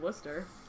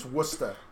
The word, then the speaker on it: Worcester: I have one in Worcester. – No, you're pronouncing that wrong. – Is it pronounced Worcester? – It's Worcester.